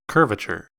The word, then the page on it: curvature: American English